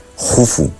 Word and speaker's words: Keops: que por cierto, para culturilla general, se escribe Keops, pero se pronuncia Jufu, y casi nadie llama Jufu, porque Herodoto nos lo tradujo del griego y bueno, pues ahí se ha quedado, ¿no?